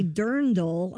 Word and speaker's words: dirndl: The dress is called a dirndl. I must have pronounced it wrong. I apologize. Dirndl is what that dress is called.